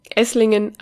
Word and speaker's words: Esslingen: Esslinge. An alle draußen, das habe ich jetzt wunderschön ausgesprochen. Esslingen am Neckar.